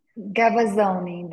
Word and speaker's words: Gavazzoni: Gavazzoni, that's correct.